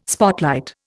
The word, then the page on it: spotlight: Indian